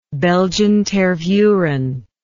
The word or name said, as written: belgian tervuren